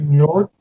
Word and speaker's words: Njord: But the grandfather, who's, we keep just calling the grandfather, I think his name was Mjordi. Does that sound right? – Njord? – That was Njord. – Yeah. N-J-O-R-D. – Oh, okay. – So that's how it's spelled.